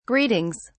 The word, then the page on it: greetings: British English